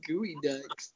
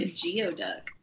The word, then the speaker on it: geoduck: Why are they also called gooey ducks? – I don't know. – And also, why is a gooey duck spelled like a geoduck? – Right, exactly. It's not pronounced Geoduck. It's pronounced Gooey Duck.